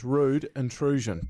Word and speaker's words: Rude Intrusion: You've got to get that right. Rudin. I know you're holding on to the past there. – Rudin. – But the fact is, it's Rude Intrusion. Two words.